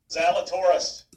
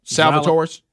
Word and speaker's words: Zalatoris: Will Zalatoris. – Zalatoris.